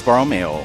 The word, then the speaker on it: Borromeo: St. Charles Borromeo.